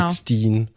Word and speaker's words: Goldstein: Goldstein ja. Englisch ausgesprochen.